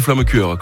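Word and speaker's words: Flammekueche: on peut dire quand même flammecure. – On peut dire, ouais, ça dépend de la prononciation. D'accord. Chez moi, Maestratheim, c'est flammecure.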